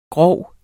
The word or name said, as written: grov